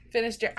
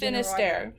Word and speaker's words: Finisterre: You had the Finisterre.